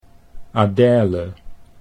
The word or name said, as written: Adele